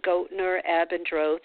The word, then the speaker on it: Goettner Abendroth: The first one is your name, Goettner Abendroth,